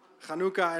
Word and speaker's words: Hanukkah: Want de Joodse feesten is onder andere Hanukkah, of hoe spreek je dat uit het Nederlands? Hanukkah, inderdaad.